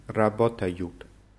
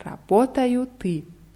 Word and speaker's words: работают: Они работают. – Ja, wichtig ist hier überall die Betonung auf dem O. Я работаю, ты работаешь, он работает, мы работаем, вы работаете, они работают.